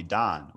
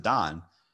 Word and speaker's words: Dan: So like when I had my sister on the show, she kept calling me Don, which is actually my name. That's what they meant to name me. But because they're French Moroccans, when they see D-A-N, they pronounce it Don.